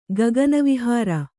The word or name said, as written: ಗಗನವಿಹಾರಿ